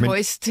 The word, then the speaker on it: Hoist: H-O-I-S-T Finance, yes. Det er svært at udtale. Vi kalder det Høst her i Millionærklubben, Lars Persson. Det er svensk, jeg ved, hvordan man udtaler som svensker, men det er altså H-O-I-S-T, hvis man selv skal ikke og kigge på den, som Lars Persson har taget i portefølje.